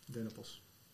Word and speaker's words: dennenappel: Ik heb de indruk dat ik meestal wel dennenappel zeg. Ja, niet zo, maar dennenappel. Dennenappel. – Dennenappel. Zonder de N, maar wel met de E.